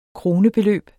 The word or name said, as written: krone